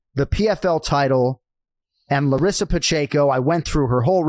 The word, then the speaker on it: Pacheco: Larissa Pacheco, am I pronouncing that correctly? – Yes, you got it.